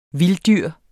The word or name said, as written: VILDDYRETS